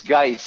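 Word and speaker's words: Guice: You were calling him Darius Goose. – Smells? – Yeah. – There you go. It's Geis, I think it's pronounced, by the way. – Spelled like juice, pronounced like Geis.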